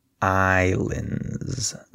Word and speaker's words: islands: Notice that the S is silent. So we say islands. There's no S sound.